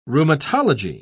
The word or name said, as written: rheumatology